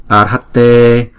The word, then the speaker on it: Arhatte: Chúng ta đọc nghe có chữ R trong đó. Arhatte Samyak Sambuddhaya Samyak Sambuddhaya.